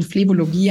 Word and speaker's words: Phlebologie: Und habe ich Phlebologie richtig ausgesprochen? – Phlebologie klingt genau, wie es sein sollte.